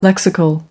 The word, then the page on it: lexical: American English